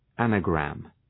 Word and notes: anagram: ‘ænə,græm

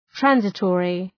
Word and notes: transitory: ‘trænsı,tɔ:rı

